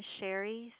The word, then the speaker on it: Cheri: First name is Sherry, C-H-E-R-I, and the last name is Milam, M-I-L-A-M.